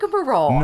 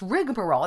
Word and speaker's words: rigmarole: It's rigmarole. – No way. – I was like mind blown on that one. Yeah. There's no A in the first part of a word. It's rigmarole. It's even spelled without the A. It's spelled R-I-G-M-A-R-O-L-E.